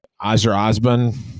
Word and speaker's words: Azerbaijan: So Formula One this weekend, we try to get this pronunciation of this country right. Azerbaijan. There we go. I got it.